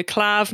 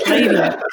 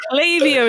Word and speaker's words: Klaviyo: I keep having to tell, they currently sponsor the podcast, and I keep having to tell myself off every time I go Clav. No, it's Klaviyo. Klaviyo is the right way to say it.